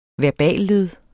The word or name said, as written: verballed